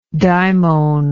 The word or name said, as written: daimonistic